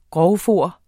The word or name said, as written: grovfoder